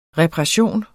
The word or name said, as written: reparation